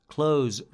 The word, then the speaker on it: clothes: Clothes، راحت بگو clothes، نیازی نیست عجیب غریب تلفظ کنی. Clothes و هر چیز دیگه نه، راحت clothes، the clothes.